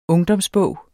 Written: ungdomsbog

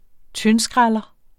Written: tyndskræller

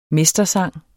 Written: mestersang

